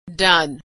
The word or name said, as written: done